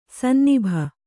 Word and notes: ಸನ್ನಿಭ: kannada

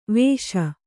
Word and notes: ವಶಿ: vaśi

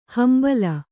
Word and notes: ಹಂಬಲ: hambala

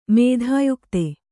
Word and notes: ಮಿಥೋಯುಕ್ತ: mithōyukta

